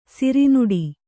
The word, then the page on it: ಸಿರಿನುಡಿ: siri nuḍi